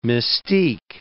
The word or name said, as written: mystique